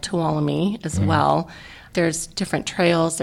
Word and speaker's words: Tuolumne: And when we try to tell people how to say it, we usually say, follow me to Tuolumne.